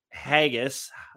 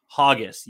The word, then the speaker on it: haggis: It's pronounced haggis, not hoggis.